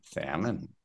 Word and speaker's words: salmon: Salmon. Perfect. Good pronunciation there. The L is silent. Okay, so it sounds like this. So just in your mind, delete the L.